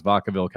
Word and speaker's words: Vacaville: I heard some people saying Vacaville, but I think it's Vacaville, California. So congratulations to Tony.